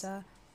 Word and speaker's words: Sousa: For $2,000, American composer John Philip how would you pronounce that last it's name? It's Sousa.